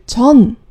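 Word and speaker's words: john (뎐): 但是当你看到文字的时候，可能会有一个小小的疑问：这最后一个字是 “dun”，它可不是 “john”。那么为什么他们在念剧名的时候都是把它念成 “john” 这个字呢？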